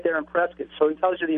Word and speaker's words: Prescott: On the map, it looks like it's pronounced Prescott, but it's Prescott.